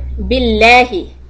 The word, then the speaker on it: billahi: And we know that the Allah word can be pronounced in two ways, soft or heavy. So you just write S for soft, because there is a kasra before the lam of Allah. So you have to say billahi, not lahi.